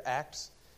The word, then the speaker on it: Acts: And just for the record, Acts. There's supposed to be a T in there, but I don't pronounce it like that. Anyone else? It's like A-C-K-S, the book of Acts.